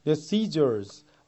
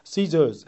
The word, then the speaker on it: scissors: Scissors, R 발음이 들리는 발음과 Scissors 해서 거의 R이 들리지 않는 발음이 있어요.